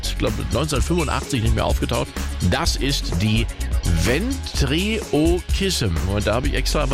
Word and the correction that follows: Ventriloquism: Ventriloquism. – Ventriloquism.